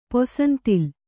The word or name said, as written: ಪೊಸಂತಿಲ್